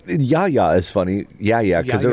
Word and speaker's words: Yahya: He's using just, I think, the standard English pronunciation. But when I looked at this a couple hours ago, I looked up the proper way to pronounce it in Arabic, and it's in Yahya.